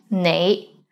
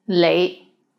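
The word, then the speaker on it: nei (你): The right way to pronounce it is nei, but in Hong Kong, many people pronounce it as lei, lei.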